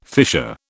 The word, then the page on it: fissure: British English